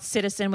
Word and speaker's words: CTZN: And it's spelled Citizen with no vowels. So it's CTZN Cosmetics, if you guys aren't familiar.